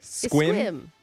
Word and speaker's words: Sequim: And you're going to be playing Nessa from Sequim. – Squim. – Why is there S-E? – That's how you say it. – Squim? – Yeah.